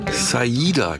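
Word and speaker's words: Siedah: Dennis Edwards und Ciara Garrett. Moment, stopp. Zurückgespult. Saida Garrett, habe ich neulich gelernt. Ich habe es auch immer falsch ausgesprochen.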